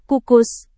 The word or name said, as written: kukus